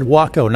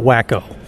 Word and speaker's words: Waco: He said Waco, not Wacko. Just so we're clear.